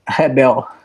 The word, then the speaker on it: Rebel: So as an American, we say Rebel. – Yes. – How do you say it with the Brazilian? – Hebel. – Hebel. It's almost like an H, huh? – Yes.